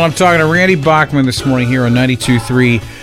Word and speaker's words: Bachman: which they then put out a thing that said Bachman, B-O-C-K, like as a pronunciation.